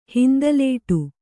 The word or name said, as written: ಹಿಂದಲೇಟು